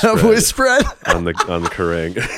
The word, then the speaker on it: Kerrang: There's this other interview that Patrick did with Kerrang. Is that how you pronounce Kerrang.